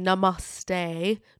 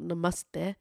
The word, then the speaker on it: namaste: Now, I have to say this the Western way, namaste, not namaste, which is how it's said, namaste, rather than namaste.